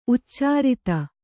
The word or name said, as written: ಉಚ್ಚಾರಿತ